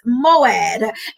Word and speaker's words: moed: moed. It's spelled M-O-E-D, moed, which literally translates and means appointed time or divine appointments.